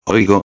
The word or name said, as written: oigo